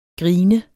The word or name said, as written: grine